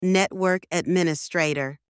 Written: network administrator

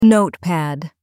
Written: notepad

